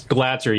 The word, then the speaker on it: Glatzer: How do I say your surname? – It's Glatzer. You had it right the first time.